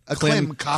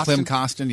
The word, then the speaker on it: Klim Kostin: If you're Jordan Kyrou, if you are Kim Kostin, isn't that Kostin? – Klim Kostin. – Klim Kostin, yeah. Don't get his name wrong.